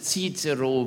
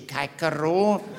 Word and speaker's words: Cicero: We correctly pronounce Cicero. We do not say Caicero.